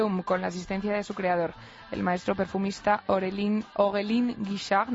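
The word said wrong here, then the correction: Aurélien Guichard: no sé si lo he dicho bien, Lola. Aurelien Guichard. – Muy bien, mucho mejor.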